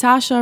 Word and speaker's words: Saoirse: Saoirse Ronan. – Saoirse. Also ja. Verstehe. Eigentlich muss man einfach nur denken wie das deutsche Sascha. So. Ja. Man darf nicht daran denken, wie der Name geschrieben wird.